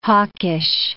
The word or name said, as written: hawkish